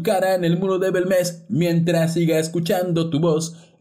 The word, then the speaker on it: Bélmez: Belmes, Belmes eh, con acento en la primera E es este...